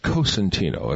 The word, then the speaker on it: Cosentino: But we do want to mention also, it's Cosentino. If you're from Cosenza in southern Italy, you're a Cosentino, a town called Cosenza.